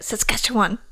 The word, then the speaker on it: Saskatchewan: Well, anyway, he was born November 9th, 1906 in Saskatchewan, Canada, which is fun to say. – It is. I don't think I could repeat it. Saskatchewan.